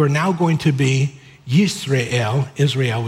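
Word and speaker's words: Yisrael (ישראל): There on your outline, El is always God, but the way it's pronounced is Yisrael.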